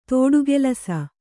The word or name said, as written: ತೋಡುಗೆಲಸ